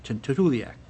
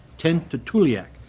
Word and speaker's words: Tuntutuliak: T-U-N-T-U-T-U-L-I-A-K, Tuntutliak, Tuntutliak.